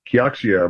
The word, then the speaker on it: Kioxia: The semiconductor spin out of Toshiba is worth, Kioxia, I believe is the correct way to say it, is now worth much more than the parent company Toshiba ever was worth.